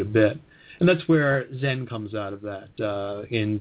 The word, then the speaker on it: Zen: And in Japan, that same word, Chan, is pronounced Zen.